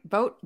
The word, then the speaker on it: Vogt: VOGT. How do you say that? Steven, right? Vote. Is that how you say his name? – Yeah, Vogata.